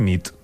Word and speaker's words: nit: Y noche es nit. – Muy bien. – Buena nit. – Buena nit, es verdad. – Buena nit.